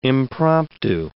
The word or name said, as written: impromptu